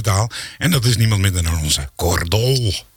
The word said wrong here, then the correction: Tol: Je wel goed uitspreken, want in Edam woont de familie Dol. – Oké. Okay. – En wij zijn de familie Tol. – Oh, je bent de Tol.